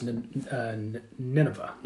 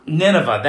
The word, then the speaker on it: Nineveh: I've heard it pronounced Nineveh. – Nineveh, that's it.